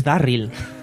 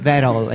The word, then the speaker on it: Darryl: Por supuesto, o Darryl, es Darryl. No es Daryl, es.